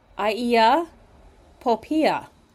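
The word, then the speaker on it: Eia Popeia: And the variety that they are selling is called, I think I'm going to pronounce it correctly, it's called Iia Poppaea. So that's spelled E-I-A, second word, P-O-P-E-I-A, Iia Poppaea.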